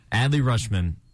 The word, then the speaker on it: Adley Rutschman: I think my player of the week is going to be Adley Rutschman. Is that how you pronounce it? – I think you got it. – Okay, cool. Adley Rutschman, MLB's top prospect, finally making his debut for the Orioles.